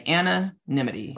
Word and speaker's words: anonymity: So this little hint was to say the word Anna and then say the word Anna Smith. And then say anonymity.